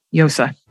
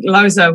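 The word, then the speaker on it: Llosa: Some people say Loza, but technically Yosa. But Yosa? Either one's fine. Yeah, the double L is a Y sound, yeah.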